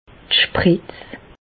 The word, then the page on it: d' Spretz: Bas Rhin; Reichshoffen